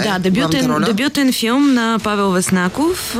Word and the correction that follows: Веснаков: или Веснаков по-точно е ударението.